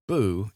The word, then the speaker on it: BU: And this episode of the podcast is called BU, or the letters BU, which we pronounce Boo, as in Boo Productions.